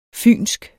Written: fynsk

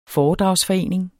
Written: FOREDRAGSFORENING

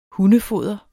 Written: hundefoder

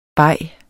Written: beget

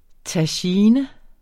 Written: tagine

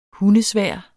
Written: hundesvær

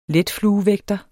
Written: letfluevægter

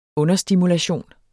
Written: understimulation

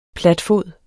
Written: platfod

